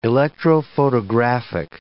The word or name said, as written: electrophotographic